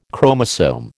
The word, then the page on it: chromosome: American English